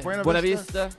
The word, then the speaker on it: Buena Vista: Yeah, dance hall right here on Lake Buena Vista. Am I saying that right? – Yeah, Buena Vista.